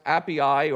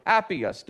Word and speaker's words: Appii: And the name of the place is pronounced Appii or Appius, depending on what translation you have.